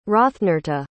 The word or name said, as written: hrothnerta